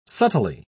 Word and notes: subtly: British English

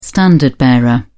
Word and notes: standard bearer: British English

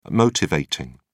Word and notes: motivating: British English

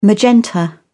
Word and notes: magenta: British English

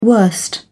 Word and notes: worst: British English

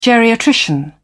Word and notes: geriatrician: British English

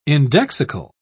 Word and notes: indexical: British English

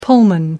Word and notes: pullman: American English

